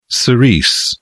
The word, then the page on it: cerise: British English